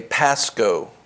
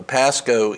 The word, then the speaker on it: pascho: So if you go through, and this word is, in English we would say pascho, P-A-S-C-H-O, pascho in the Greek. We would pronounce it like that way, pascho.